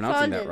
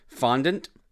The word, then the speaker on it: fondant: I don't know if I'm pronouncing fondant. That right. Fondant. – Fondant.